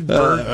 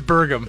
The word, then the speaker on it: Burgum: Did I say it right? – Doug Burgum. – All right. – Yeah, it is Burgum.